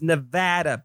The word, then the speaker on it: Nevada: And it's Nevada, people. Not Nevada, Nevada. Nobody from Nevada pronounces it Nevada. It's Nevada.